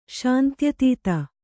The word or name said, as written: ಶಾಂತ್ಯತೀತ